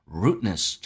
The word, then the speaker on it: rudeness: Rudeness 这个单词呢，地不发音，因为 rude 那个的跟后面的 n 两个辅音只用发后面一个，所以气流阻塞去掉 d 的时候叫 rudeness。